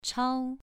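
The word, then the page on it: chao: tone 2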